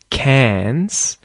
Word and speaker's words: Cairns: A lot of people mispronounce the place name Cairns. There's no R pronunciation there. Cairns.